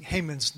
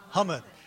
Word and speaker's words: Haman: You know, when I first saw that, I was wondering if he was pronouncing Haman's name right. He says what? Haman. And then I heard Netanyahu. Anybody seen Netanyahu's speech? He said Haman. So, okay, he's right. I'm wrong. He's right.